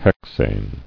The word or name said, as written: Hexane